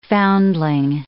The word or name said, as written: foundling